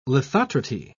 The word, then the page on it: lithotrity: British English